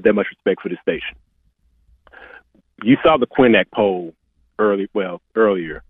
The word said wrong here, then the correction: Quinnipiac: The Quinnipiac poll?